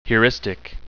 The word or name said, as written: heuristic